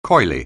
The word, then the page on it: coyly: anglais